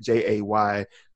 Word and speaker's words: Javann: So it was like spelled out, like my name is spelled Javann, but if you say it Jayvin, like Jay apostrophe on that for emphasis, Vin.